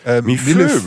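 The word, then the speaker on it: Millefeuille: Wie spricht man es aus? Mifeu, dieses französische Ding? – Mifeu, würde ich sagen. Ja, es wird Millefeu geschrieben. Mifeu, würde ich jetzt sagen. Aber da, Mifeu.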